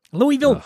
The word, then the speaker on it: Louisville: You know, I also appreciate that somebody from Philly is actually pronouncing Louisville right. – Louisville.